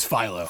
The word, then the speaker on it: Philo: Or is it Philo? – It's Philo. – Philo Grant, played by Michael Wincott. – Like a Philo Fax.